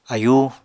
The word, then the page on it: ayú: apatani